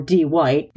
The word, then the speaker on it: Dwight: Brewster or D. White, actually spelled Dwight, but he insisted everybody pronounce it D. White because it's cool, standing outside her unit, somehow Bluetooth punking her with her phone.